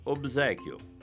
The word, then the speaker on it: obséquio: O mesmo aconteceu com a palavra obséquio, já mencionada neste programa, em que o B sonoro sonorizou o S em Z, BZ. Deve-se pronunciar obséquio.